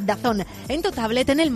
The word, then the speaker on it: DAZN: DAZN, pero se pronuncia Dazón.